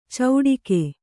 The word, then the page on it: ಚೌಡಿಕೆ: cauḍike